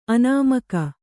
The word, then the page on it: ಆನೆಮುಗ: Kannada